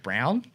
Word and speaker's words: Braun: He spent most of his life designing for Braun is how we say it here in Australia, but it's pronounced Brown.